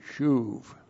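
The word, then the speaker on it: shuv (שוב): S-H long U-B, shuv.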